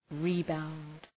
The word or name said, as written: rebound